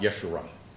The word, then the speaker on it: Yeshurun: It's actually with a J, but it's pronounced Yeshurun.